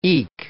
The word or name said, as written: eke